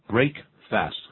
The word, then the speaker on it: breakfast: 那把 the 去掉，然后呢就变成 break fast 合在一起，念成 breakfast，那个 fast 反而念成 first。